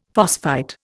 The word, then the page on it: phosphite: Indian